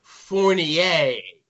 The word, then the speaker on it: Furnier: And like I said, all because, you know, everybody equated Alice Cooper to the character Vincent Furnier. I'm not sure how to say his real name. – Fournier. – Yes. – It's French.